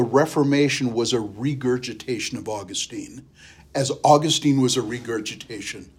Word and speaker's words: Augustine: Augustine is how it's actually pronounced, but I learned it, Augustine.